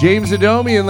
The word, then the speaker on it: Adomian: Wow, it's James Adomian, ladies and gentlemen. Do I say your name right? – Happy Honda Day. – Yes.